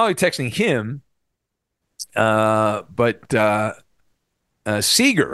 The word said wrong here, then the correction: Sauger: Sauger, Sauger, Sauger, Sauger. Yeah, When he first got hired, they're like, think of soccer when you say his name.